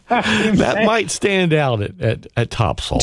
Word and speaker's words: Topsail: It's spelled Topsail, but if you're from around here, it's Topsail. Yeah.